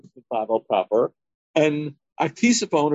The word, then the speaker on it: Ctesiphon: Yeah, Ctesiphon. Now, in English, the C is silent. It's interesting, though, in the Gemara, you pronounce Ctesiphon, so it's preserving whatever language it was, and that was the capital of the Parthians near Baghdad.